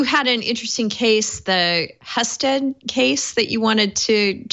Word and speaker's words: Husted: This is Philip Randolph Institute versus, I think it's pronounced Husted, but you know, Husted.